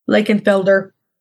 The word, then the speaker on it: Lakenfelder: You'll see the name sometimes spelled with an F. Again, that's the German spelling. Instead of that V, still mostly pronounced as an F, Lakenfelder.